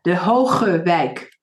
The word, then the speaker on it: De Hoge Wijk: Yes, and it's very difficult from people from abroad to pronounce the G, which is very prominent in the Dutch language. But that's also why we were dubbed Dementia Village, because people can't pronounce the Hoge Wijk.